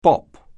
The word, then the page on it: bop: anglais